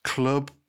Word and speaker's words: Clwb: So apparently you say L and W. So for those who don't know, it's spelled C-L-W-B. But apparently the L and W you say is club.